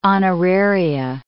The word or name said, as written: honoraria